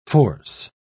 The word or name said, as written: force